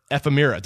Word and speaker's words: ephemera: So a writer of food, fiction, travel, and essay, struggling photographer, a frustrated novelist, and a lover of diner ephemera. Did I say it right that time? – Ephemera.